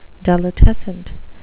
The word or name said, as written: delitescent